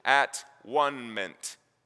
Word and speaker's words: atonement: I had a teacher a long time ago in school that said, you're mispronouncing that word. The word is at-one-ment. At-one-ment.